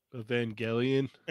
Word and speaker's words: Evangelion: Okay, so you got the uh how do you say it? Evangeline. Evangelion. okay, thank you.